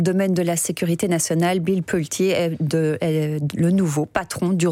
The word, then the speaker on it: Bill Pulte: C'est pas Bill Poole, mais Bill Pulte, un riche homme d'affaires, ami de Trump et désormais chef du renseignement américain.